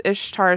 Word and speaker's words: Ishtar: Eorthian says Ishtar is pronounced like Easter in the Middle East.